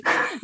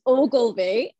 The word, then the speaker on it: Ogilvie: Ogilvie. No, it's fine. It's a Scottish name. People always look and go, like, how do you say that? So it's Ogilvie.